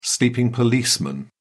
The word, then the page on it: sleeping policeman: British English